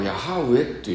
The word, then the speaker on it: ヤハウェ: それをヘブライ語ではヤハウェっていう発音になるわけですね、でそれを間違って翻訳したからエホバっていう発音になったわけですけども、元来はこれヤハウェと発音すべきなんですね。だからといって旧約聖書、明治時代にこれ翻訳した人ってエホバって翻訳してますけど、まあ、それが間違いだと、まあこうヤハウェという本来は読むべきところをエホバというふうにまあ読んでしまったんだというふうに、まあ理解すればいいだけですね。